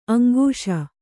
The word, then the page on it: ಅಂಕಿಸು: aŋkisu